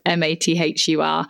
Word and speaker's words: Mathur: I'm on Instagram as @AnnaMathur, and the surname is Mathur. It's an Indian surname. It's not Martha. I often have to say it's like Martha, like a girl's name, but not spelled like that.